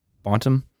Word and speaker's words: Bonton: Bonton? B-O-N-T-O-N? – Mm-hmm.